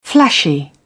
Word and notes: flashy: British English

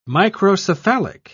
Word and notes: microcephalic: British English

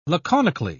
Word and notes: laconically: British English

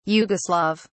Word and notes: yugoslav: British English

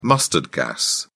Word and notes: mustard gas: British English

